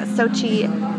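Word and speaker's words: Xochi: It's spelled Sochi, X like X-ray, O-C-H-I.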